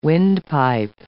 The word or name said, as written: windpipe